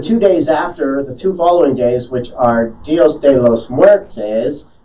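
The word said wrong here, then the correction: Día de los Muertos: Am I saying that right? – No, you are not. – No, Dia de los Muertos.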